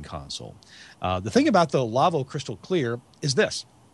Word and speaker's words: Lawo: Their website is LAWO, pronounced Lavo.